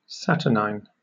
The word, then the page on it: saturnine: British English